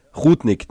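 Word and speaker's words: Rudnik: Also ich muss sagen, die Barbara Rudnick ist mir da fast, na wie? Rudig. Spinni? – Muss auf ihn scrollen. – Ja. Ja. Rudnick, na, habe ich schon richtig gesagt, ja.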